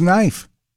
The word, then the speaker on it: knife: Then there's Knife. The K is silent.